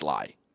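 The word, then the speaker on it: Le: For the longest time, I said Lee. I thought it was Bruce Lee because of Bruce Lee, right? Apparently, Bruce Lai himself in an interview pronounced it Bruce Lai. – Okay. – So I'm going with Bruce Lai.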